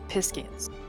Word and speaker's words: Piscean: And then you've got the completely new race called the Piscians. So it's like, I see it like sort of Pacific, P-I-S-C-E-A-N, Piscian.